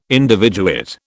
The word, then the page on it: individuate: British English